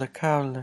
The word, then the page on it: lekaule: Lwaitel